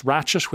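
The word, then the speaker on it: Ratched: The institution's personified by a woman, Nurse Ratchet, which rhymes with hatchet.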